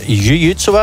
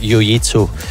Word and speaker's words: jiu-jitsu: Anja, eigenlijk wordt het jiu-jitsu uitgesproken, maar in de meeste clubs in Vlaanderen zeggen ze toch wel jiu-jitsu.